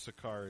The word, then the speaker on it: Sychar: I was just going to try and pronounce that properly, but I think it's Sychar is the right pronunciation.